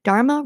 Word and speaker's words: Dharma: Dharma rhymes with karma.